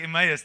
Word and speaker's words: Emmaus: How do we say that? Help me. Emmaus. Yes, I'm like, Afrikaans brain's kicking in here. Okay, it's like Emmaus.